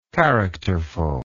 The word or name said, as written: characterfully